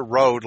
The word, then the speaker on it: Rowd: It's Winter Road, like R-O-A-D, but it's spelled R-O-W-D.